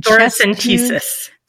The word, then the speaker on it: thoracentesis: Thoracentesis and thoracentesis.